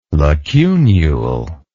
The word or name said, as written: lacunule